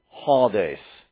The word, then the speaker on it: Hades: You would actually, in Greek, pronounce it Hades. We're going to stay with the anglicized form of that.